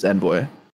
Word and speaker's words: Envoy: So Envoy. But I mean, the American way to pronounce it is Envoy.